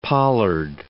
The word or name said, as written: pollard